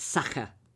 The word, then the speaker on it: Sacher: apart from a Sasha Torte in Salzburg. They were wonderful. – Okay, wonderful. I'm just going to correct you on your pronunciation of Sacher Torte. It's Sacher.